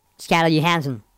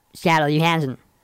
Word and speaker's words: Scarlett Johansson: Scarlett Johansson. – ¿Me lo repites? A ver si lo pillo un poco. – Scarlett Johansson.